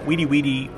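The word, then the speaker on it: wiri wiri: Weedy Weedy was, and it's spelled W-I-R-I, Weedy Weedy was a term that my father used in our house whenever we were speaking too much English.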